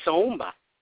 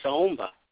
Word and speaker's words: Sahumba: When I was at the World Fair, my name was Saumba, Raleigh Saumba, and how do you spell it? – S-A-H-U-M-B-A. – Oh, okay. – S-A-H-U-M, Saumba.